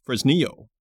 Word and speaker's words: Fresnillo: It sounds like 20, 25 kilometers from the oldest mine on the planet, which dates back to 1550, Fresnillo, as Bruce explained the pronunciation to me.